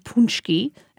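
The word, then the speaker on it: pączki: It's spelled P-A-C-Z-K-I, but pronounced Punchki.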